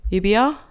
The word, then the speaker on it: Namibia: Namibia.